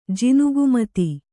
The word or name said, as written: ಜಿನುಗುಮತಿ